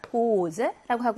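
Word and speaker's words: Hose: Hose의 S는 단어 음절 중간에 있네요. 그럴 때는 뒤에 나오는 알파벳의 종류를 본다라고 했거든요. 모음 앞에 있는 S는 무슨 발음? Z 발음을 내게 됩니다. Hose라고 하고요.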